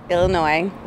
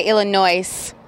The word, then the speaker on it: Illinois: All my life I've said Illinois. – Does it catch in your ear when you hear these people saying Illinois lately? – No, just when they say Illinois with an S. The S is silent.